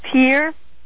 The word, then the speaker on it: Pierre: 1604 Hilltop Drive, Pier, I spell it for you, P-I-E-R-R-E. – T-I-E-R-R-E, right.